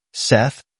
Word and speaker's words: Seth: Seth rhymes with gate.